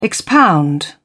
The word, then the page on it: expound: British English